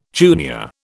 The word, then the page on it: junior: British English